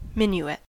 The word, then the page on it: minuet: American English